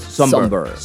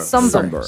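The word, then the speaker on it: Sombr: Pero a mí siempre me sacó de onda su nombre, pero es Somber. – Somber. – Somber. – Se escribe S-M-B-R, ¿no? – No, S-O-M-B-R. Y es Somber. Como... – Somber. Somber. Es como sombra sin la A.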